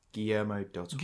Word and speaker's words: Guillermo del Toro: Guillermo del Toro.